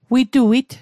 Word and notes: wituit: Mi'gmaq

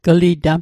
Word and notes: glitam: Mi'gmaq

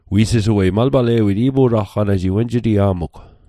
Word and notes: pu'talqanatl: Mi'gmaq

